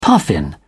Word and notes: puffin: British English

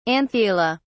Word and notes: anthela: British English

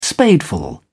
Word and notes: spadeful: British English

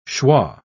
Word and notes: shwa: British English

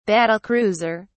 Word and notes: battle-cruiser: British English

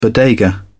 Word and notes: bodega: British English

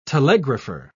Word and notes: telegrapher: British English

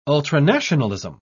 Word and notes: ultranationalism: British English

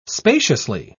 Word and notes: spaciously: British English

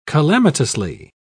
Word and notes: calamitously: British English

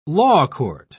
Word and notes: lawcourt: British English

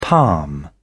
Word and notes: palm: British English

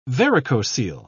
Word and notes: varicocele: British English

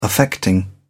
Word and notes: affecting: British English

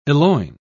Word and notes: eloign: British English

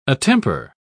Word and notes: attemper: British English

